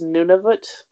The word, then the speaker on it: Nunavut: But I guess it's more correctly pronounced Nunavut, but it doesn't, it didn't work.